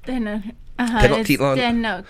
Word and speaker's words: Tenochtitlan: Tenochtitlan.